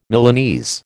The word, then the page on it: Milanese: American English